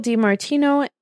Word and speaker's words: DiMartino: And Michael D. Marino and Brian. I'm going to butcher that name. Kotznik? – I don't know where you're finding it. – Should be right there. There it is. – Michael DiMartino and Brian Konietzko.